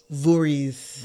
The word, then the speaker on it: Voorhees: Voorhees.